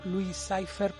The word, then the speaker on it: Louis Cypher: Este nombre en la película lo pronuncian como Louis Cypher porque en inglés se pronuncia parecido a Lucifer.